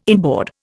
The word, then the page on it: inboard: Indian